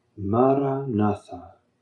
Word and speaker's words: Maranatha: And the word I recommend you to say, Maranatha. Four equally stressed syllables. Maranatha.